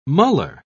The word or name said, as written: muller